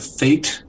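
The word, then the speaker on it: fête: It's a fete or fete. Fete's more appropriate, but actually they pronounce it fed.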